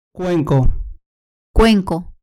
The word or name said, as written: cuenco